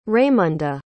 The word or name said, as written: raimunda